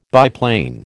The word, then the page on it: biplane: American English